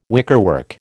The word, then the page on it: wickerwork: American English